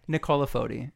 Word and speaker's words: Nicola Foti: Okay, let's hear it. – Nicola Foti. – Nicola Foti? – Mm-hmm. Nicola. – Nicola. Yeah.